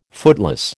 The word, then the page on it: footloose: American English